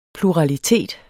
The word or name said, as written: PLURALITETS